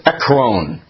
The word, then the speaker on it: Ekron: You pronounce this Ekron. Long O, please.